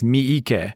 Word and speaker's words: Miike: Okay, today we are talking about the 2017 samurai action film, Blade of the Immortal, directed by the one and only Takashi Miike. Did I say it right? – Takashi. I think it's Miike.